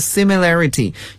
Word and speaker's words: similarity: 那么通常 similar 我们也可以把它变作名词 similarity，也就是在 similar 的词尾添加 ity 这个拉丁语中的名词后缀 ity，可是发音呢就要发生了变化，形容词 similar，名词 similarity。